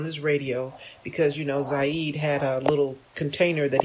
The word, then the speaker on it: Zayd: So this police officer decided to stop Zayd Atkinson. Hope I'm pronouncing his name correctly. Z-A-Y-D is how he spells his first name.